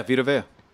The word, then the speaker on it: Vita Vea: I'm saying his name right? – Yeah, Vita Vea.